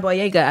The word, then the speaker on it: Boyega: even John Boyega, I don't know if I say his name right.